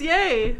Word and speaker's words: Ye: Better be Ye. – It's Ye.